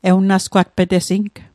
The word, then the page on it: ewnasgwatpetesing: Mi'gmaq